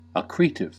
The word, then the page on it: accretive: American English